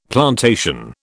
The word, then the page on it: plantation: British English